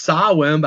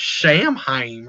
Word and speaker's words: Samhain: I'm being told by my wife that it's pronounced Sawin, but I don't need some bitch telling me how to say my words. It's got an M in there. That's Samhain right there.